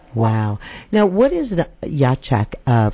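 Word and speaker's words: Iachak: Tell me, did I pronounce that right, Ichak? Ichak? – It's Yachak. – Yachak, okay.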